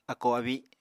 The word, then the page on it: akó-abi: apatani